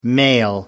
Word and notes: male: American English

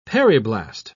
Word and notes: periblast: British English

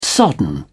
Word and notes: sodden: British English

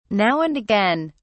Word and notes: now and again: British English

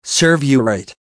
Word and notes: serve you right: American English

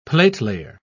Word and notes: platelayer: British English

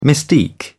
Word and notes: mystique: British English

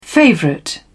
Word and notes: favourite: British English

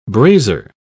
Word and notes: brasier: British English

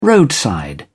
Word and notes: roadside: British English

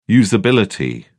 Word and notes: usability: British English

